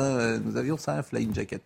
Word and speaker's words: flying jacket: Je remarque, que vous avez parfaitement prononcé flying jacket. Oui, flying jacket. Donc, c'est un mot anglais que vous prononcez très bien grâce à une chanson française.